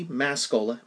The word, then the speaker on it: Mascola: Same deal, type my name, Andy Mascola. My last name is spelled M-A-S as in Sam, C-O-L-A as in Coca-Cola.